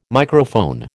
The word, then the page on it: Microphone: American English